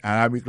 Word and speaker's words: Arabic: Prononciation correcte là, c'est arabic.